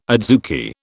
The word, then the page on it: adzuki: British English